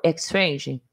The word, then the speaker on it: Exchange: EPE, que é o Erotic Power Exchange. Falei certo? – Exchange. – Exchange, isso. Ou PPE,